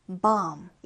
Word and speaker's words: bomb: bomb 이렇게요. 이때 B 발음은 나지 않고 묵음 처리입니다.